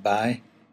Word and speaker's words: boy: We tend to say boy a lot in County Down, only we pronounce it bye.